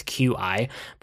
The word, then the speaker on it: Qi: So I got one of these, I think they're pronounced Qi chargers. It's Qi, but one of the wireless chargers.